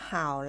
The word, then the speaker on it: 好: เห่า